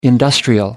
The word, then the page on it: industrial: American English